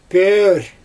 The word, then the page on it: pöör: Xyzyl